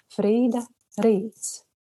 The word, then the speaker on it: Frīda Rīts: Ar mums kopā izlasīja arī mm, Anetes Bookshop un Fried, Friderīts. Un, oh, slikti izrunāju kaut kā šo, šo nosaukumu. Frīda Rīts. Frīda Rīts.